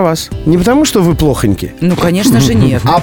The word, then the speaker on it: плохонький: Ну а что касается произношения и э, рекомендаций для нас с вами, для тех, кто перед микрофоном, то плохонький. – плохонький. Рекомендуется в словарях для работников эфира.